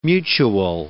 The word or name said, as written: mutuel